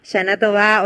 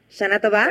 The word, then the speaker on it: Shana Tová: Shana Tová, o sea que lo dijimos bien, lo dije bien. Shana Tová.